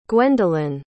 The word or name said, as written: gwendolyn